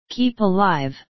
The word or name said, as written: keep alive